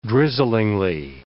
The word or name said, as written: drizzlingly